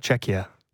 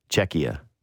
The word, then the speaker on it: Czechia: How do you say it again? – Czechia. – Czechia. – Yeah. – Czechia yourself.